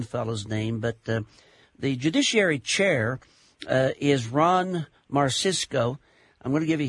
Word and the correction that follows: Marsico: It's Joe Petracca. – Okay. – And it's Ron Marsico.